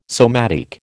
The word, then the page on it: somatic: American English